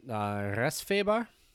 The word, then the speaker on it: resfeber: So this one is, I think they would pronounce it Resfeber. – That sounded real German. – I don't know how else to say it. I don't know. It's R-E-S-F-E-B-E-R.